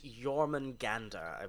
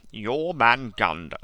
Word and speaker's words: Jormungandr: If it helps, it's Jormungandr, I believe. – Jormungandr.